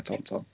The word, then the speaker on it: tauntaun: Looks like you're riding a tauntaun. – I'm going to mention to you, what, what, did you say then? How, how'd you pronounce it? – Ton ton. Yeah. – So it's tauntaun.